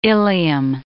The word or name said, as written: ilium